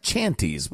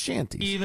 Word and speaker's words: shanties: It looks like chanties, but it's pronounced shanties.